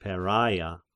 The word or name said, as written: Peraia